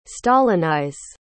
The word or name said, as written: stalinize